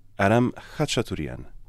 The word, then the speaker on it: Aram Khachaturian: Aram Khachaturian.